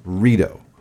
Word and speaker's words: Rideau: No, it's It's Rideau. From the French word Rideau,